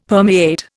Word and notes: permeate: Indian

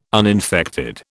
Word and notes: uninfected: British English

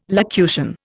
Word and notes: locution: Indian

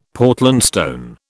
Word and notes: Portland stone: British English

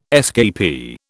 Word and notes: escapee: British English

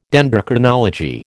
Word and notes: Dendrochronology: American English

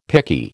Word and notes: picky: American English

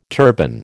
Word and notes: turban: American English

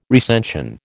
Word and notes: recension: American English

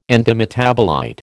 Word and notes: antimetabolite: American English

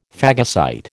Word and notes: phagocyte: American English

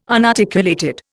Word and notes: unarticulated: Indian

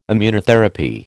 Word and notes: Immunotherapy: American English

